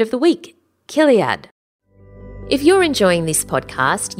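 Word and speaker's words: chiliad: Now that's actually spelt C-H but pronounced with like a K or a C. C-H-I-L-I-A-D. Kiliad.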